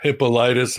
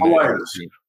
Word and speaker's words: Hippolytus: Hippolytus and Irenaeus. Hippolytus.